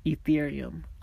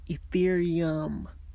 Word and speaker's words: Ethereum: Ethereum. Oh, sorry. I gotta have to pronounce that right. Ethereum. Ethereum. I guess it's my Mexican accent.